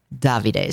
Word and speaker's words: Davide: Davide is the correct pronunciation, in bronze.